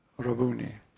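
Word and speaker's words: Rabboni: But in the pronunciation, and it's amazing that he did this, of the Galileans, Rabuni.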